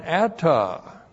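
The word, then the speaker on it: Atta (אתה): And it's joined by a makef to the personal pronoun atta. Looks like that in the Hebrew. Aleph, A-T-T-A-H. Atta. And atta means you.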